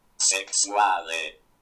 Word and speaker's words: Sexwale: Ich gebe dir mal ein Beispiel und dann, wie man es richtig ausspricht, in. Ja, okay. – Sexuale. Sexuale. Sexuale. – Also ich höre da so Sexuale, Sexuale.